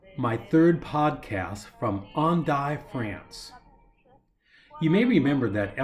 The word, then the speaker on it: Hendaye: Hendaye is pronounced Hendaye, though if you were to spell it the way we would say it in America, it looks like Hendaye, but the correct pronunciation is Hendaye.